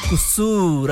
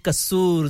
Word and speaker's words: قصور: کاف کے اوپر پیش ہم ڈالتے ہیں صحیح تلفظ کے لیے قصور اکثر کہتے ہیں نا قصور ضرب جو ہوتی ہے نا وہ نہیں ہوتی زبر پیش ہوتی ہے قصور